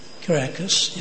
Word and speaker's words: Caracas: Is that how you pronounce it? Caracas, yeah.